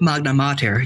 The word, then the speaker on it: Magna Mater: Magna Mater.